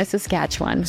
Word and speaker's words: Saskatchewan: I live in Regina, Saskatchewan.